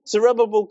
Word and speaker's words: Zerubbabel: And in this way, Zerubbabel, that's how you say it, isn't it? Zerubbabel got it right.